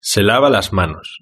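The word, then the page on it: se lava las manos: Spain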